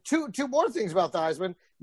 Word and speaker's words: Theismann: Number one, it's actually pronounced Theismann. – Oh, really? – They started pronouncing it Theismann in college because his college coach was like, Theismann rhymes with Heisman. And if you play by Theismann, you'll win the Heisman.